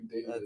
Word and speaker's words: Dawid: So then the teacher had to send out a letter to everyone's parents telling them how to pronounce my name correctly, which is technically David.